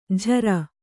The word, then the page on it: ಝರ: Kannada